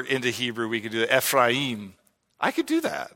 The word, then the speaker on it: Ephraim: It is Ephraim. It is not Ephraim. Do not say that, please. So it is not E-P-H-R-I-U-M. The A and an I form together, Ephraim. So if the way I say it bothers you, repent.